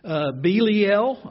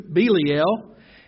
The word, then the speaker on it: Belial: What accord, or what in common, has Christ with, some people say, Belial? You can also pronounce this Belial. I think that's the correct pronunciation in the Hebrew, Belial.